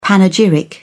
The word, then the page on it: panegyric: British English